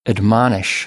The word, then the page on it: admonish: American English